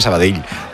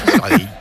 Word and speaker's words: Sabadell: El cachondo de Kiki Lisa me dice, pronuncias muy bien Sabadell. Mis respetos. – Sabadell. A mí me lo decían mucho cuando estaba en Primera Federación, porque yo también decía Sabadell, que es como se dice.